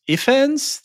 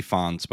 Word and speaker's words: Ifans: Because the way that Rhys Ifans, the actor, is that how you pronounce his name? – Yeah, I think maybe Ifans, but whatever. – Ifans.